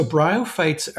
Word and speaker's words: bryophytes: Bryophytes? – Yeah. So bryophytes are the broad term of mosses, liverworts and hornworts.